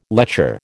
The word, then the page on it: lecher: American English